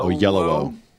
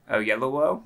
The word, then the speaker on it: Oyelowo: David Oyelowo. – Oyelowo. – Oyelowo.